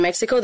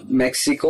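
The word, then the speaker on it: México: So, uh, so is it is it uh, pronounced like uh, Mexico or Mexico? oh my God, you have a good accent. so Mexico is English and Mexico is uh, like it's Spanish.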